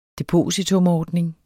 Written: depositumordning

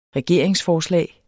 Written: regeringsforslag

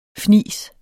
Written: fnis

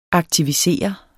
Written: aktiv

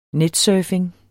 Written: netsurfing

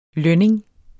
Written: lønning

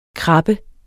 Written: krabbe

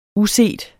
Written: uset

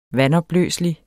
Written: vandopløselig

